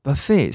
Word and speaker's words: buffet: buffet 是美国人的念法，英国人念得很特别哦，他重音跑到前面了，buffet，buffet，或者念 buffet，buffet。